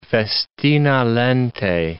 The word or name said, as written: festina lente